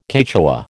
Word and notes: Quechua: American English

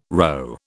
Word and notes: rho: British English

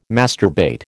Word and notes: masturbate: American English

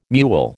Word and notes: mewl: American English

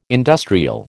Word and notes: industrial: American English

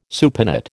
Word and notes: supinate: American English

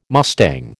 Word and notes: mustang: American English

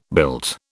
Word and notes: built: British English

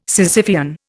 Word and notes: Sisyphean: Indian

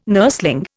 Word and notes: nurseling: Indian

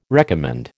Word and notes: recommend: American English

